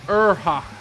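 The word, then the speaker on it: Aorlhac: Yeah, Arhalok, or the fuck you say their name. It's pronounced Urhok. It's actually the name. It's almost the name of the town where they're from.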